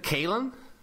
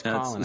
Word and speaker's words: Kalen: Isn't it Kalen? – No, it's Colin. – It's Colin. – It's Colin. Spelled Kalen, but it's pronounced Colin.